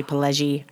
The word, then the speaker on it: Pileggi: I'm going to say Pileggi. I think I'm saying it wrong. – That's right.